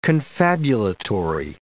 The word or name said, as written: confabulatory